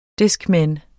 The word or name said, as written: discman